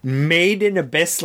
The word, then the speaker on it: Made in Abyss: And just because of the way I pronounce things, it is Made in Abyss. Like, if it was a product, you would turn it over on its bottom and it would say Made in, like, the Abyss, as opposed to it is Abyss where we throw young unmarried women.